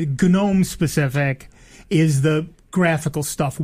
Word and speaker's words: GNOME: And they pronounce the G of GNU, GNU. – Mm-hmm. – And so it's GNOME.